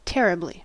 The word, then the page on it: terribly: American English